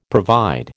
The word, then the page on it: provide: American English